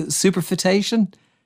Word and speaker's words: superfetation: Did I pronounce that correctly? Superfetation? – You did, yes. Yeah. Some people go similar, but yeah, it's superfetation. Well done.